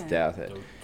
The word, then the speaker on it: Douthat: Is that how you pronounce it? – I think so. – Douthat? Douthat, right? It's Douthat.